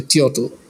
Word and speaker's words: teotl: Since we are closer to this context, the Nahuatl word for the divine spirit is teo, like T-E-O-T-L, teotl.